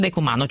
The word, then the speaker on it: Decumano: sul Decumano ci sono i famosi padiglioni stranieri. Ho detto bene? – Perfetto, qui ritorniamo ai romani, Cardo e Decumano era come venivano divise le città dei romani, sì.